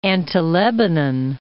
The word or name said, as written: Anti-Lebanon